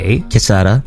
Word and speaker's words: Quesada: Is that how you say your name? Quesada? Is it Quesada? Adrian. – Is it spelled with a Q? – It's Q-U-E-S-A-D-A. – Quesada. – Quesada. So Adrian Quesada, and then the last name Michelena. M-I-C-H-E-L-E-N-A.